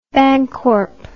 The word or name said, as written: bancorp